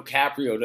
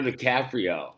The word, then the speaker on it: DiCaprio: Leonardo DiCaprio. – Okay. – I said it right both times. – No, I think the first time you said DiCaprio or something, you added an O there.